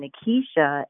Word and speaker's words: Nikisha: Many people confuse my name's pronunciation with a more well-known name, Nikita, spelled N-I-K-I-T-A. The small difference between Nikisha and Nikita is the letter I that sits between the T and A at the end of my name. The T-I-A is pronounced sha. Words that have a sh pronunciation in the English language using the letters T-I-A at the end are militia, minutia, and inertia, just to name a few as a fun fact.